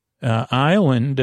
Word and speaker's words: island: So don't spell it dime, even though it's said dime, it's D-I-G-M. From the Greek, Daima, maybe D-E-I-G-M-A. Island, it's the letter S is silent, I-S-L-A-N-D. It's from Isle, I-S-L-E.